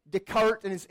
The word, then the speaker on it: Descartes: No matter what Descartes, or if you want to be phonetically correct, Descartes and his fellow philosophers say, God was not like a big watchmaker.